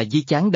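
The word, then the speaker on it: di chán (貂蝉): Điêu thuyền, đọc là di chán đồng âm với điêu tài, đọc là điêu cái.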